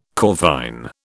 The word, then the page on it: corvine: British English